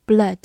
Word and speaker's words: blood: 最后拓展一个跟它相关的词，b l o o d 读作 blood。 o o 字母组合在这里发短音啊。